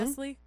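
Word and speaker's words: Nestlé: and one of the most popular brands of the product, currently made by Nestle or Nest. That's how you say it, right? Hmm? Nestle. Yeah. Okay. Just want to make sure. I always read it as a kid as Nestle.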